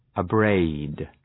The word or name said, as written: abrade